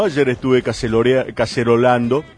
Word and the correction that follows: caceroleando: Caceroleando. – ¿Cómo es? ¿Cómo se dice? – Caceroleando. – Caceroleando.